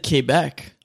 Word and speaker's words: Quebec: You even said Quebec. – Yeah, well, that's how you say it.